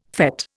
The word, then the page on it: fête: Indian